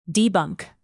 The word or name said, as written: debunk